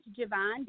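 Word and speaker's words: Gavanne: My name is Javon Davis, so it's spelled G-A-V as in Victor, A-N-N-E, last name Davis, and that is the Instagram handle, Twitter handle, Facebook handle.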